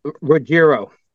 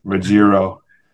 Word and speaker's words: Ruggiero: Ruggiero. – Ruggiero. – No. – John Ruggiero. – Ruggiero. – So how do you pronounce it? – I don't know. There's like five different ways to say it, I guess.